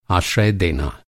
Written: आश्रय

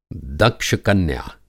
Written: दक्ष-कन्या

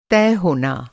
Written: तय

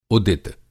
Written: उदित

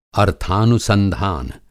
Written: अर्थानुसंधान

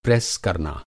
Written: प्रेस

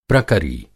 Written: प्रकरी

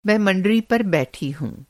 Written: मँडरी

